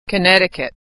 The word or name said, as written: Connecticut